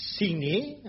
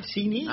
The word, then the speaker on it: Signy: Yes, and there's Sini. How do you pronounce that? S-I-G-N-Y? Sini? – I should think so.